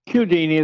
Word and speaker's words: Cudini: Am I saying that Italian right? – Cudini is the way he pronounces it.